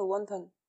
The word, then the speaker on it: wonton: Rondon, en français, on connaît ça plutôt sous le nom de wonton. Wonton. Rondon, c'est la prononciation à la roi, donc mm. euh, dans le chinois euh, standardisé du nord de, la Chine en fait.